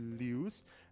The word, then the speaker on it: Lewis: E aqui também cabe falar que eu acostumei a chamar Lewis de Lewis, é, mas se você for ver a pronúncia correta lá em inglês, seria algo como Lewis.